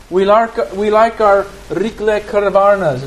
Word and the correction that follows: rýchlovarná konvice: Is that how you say it here? Rikle konvice. – Konvice, rýchlovarná konvice. Yes.